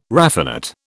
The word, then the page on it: raffinate: British English